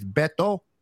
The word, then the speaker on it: Beto: Uh, uh, but just want to correct uh, for those that kind of hard hard to pronounce my name is Beto, not Beto. Beto.